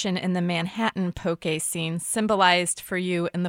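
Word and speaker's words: poké: But yeah, traditionally, there is no accent. It's the accent aigu on the E to as a nod to people that it's pronounced poke.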